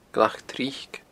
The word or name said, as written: Glac Tric